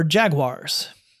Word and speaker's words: Jaguar: On Monday of this week, the CEO of Jaguar, the British car company, announced a new plan for Jaguars. That's the way we Americans say it.